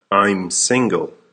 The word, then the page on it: I'm single: American English